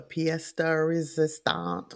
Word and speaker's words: pièce de résistance: The pièce de résistance.